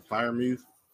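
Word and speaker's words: Freiermuth: So, Keep an eye out for that Pat Freshmuth. I don't know how to say his name. – Firemuth. – Yeah, Firemuth.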